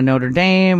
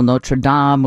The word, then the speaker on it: Notre Dame: We call the school in Indiana Notre Dame with a long A, rhymes with same, and we call the Paris Cathedral Notre Dame with a short A, rhymes with Dom, like Dom DeLuise.